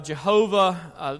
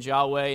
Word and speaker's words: Yahweh: Historically, we've translated the name of God Jehovah. Scholars found out in the 50s or earlier that wasn't a proper pronunciation, the proper pronunciation Yahweh, and yet no modern translations use that word.